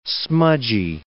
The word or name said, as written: smudgy